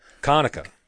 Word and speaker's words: Connaker: your last name would be pronounced like the camera, Conacher, right?